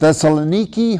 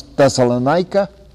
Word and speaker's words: Thessaloniki: So we begin with Thessaloniki, who most Americans pronounce Thessalonica, and we go on to Athens, and then to Corinth.